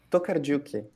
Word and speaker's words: Tokarczuk: Bom, Camila, para a gente começar a falar um pouquinho sobre, sobre os ossos dos mortos, esse livro da Olga, é, esse sobrenome que eu tenho dificuldade de pronunciar, Tokarczuk. É Tokarczuk. Tokardiuk, ótimo. Isso.